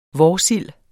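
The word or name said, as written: vårsild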